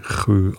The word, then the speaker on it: sjö: Der Storar nahm den Insjö, in Inchö, glaube ich, so spricht man das aus, S-J-Ö, ist das schwedische Wort für See. Und das spricht man Chö aus, irgendwie so ganz komisch.